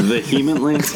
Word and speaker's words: vehemently: Yeah, vehemently. They disagree. It's early. – That's a subtitle right there. – Yeah, you put the. It sounds like you put the emphasis on the wrong syllable there, but continue.